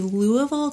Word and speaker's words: Louisville: There's going to be great debate about how to say it. You're going to hear it all. – It's going to be a thing. – Locals, even Louisville. Louisville is perfectly acceptable. Louisville. That's what we hear around here. Louisville. Two syllables, Louisville.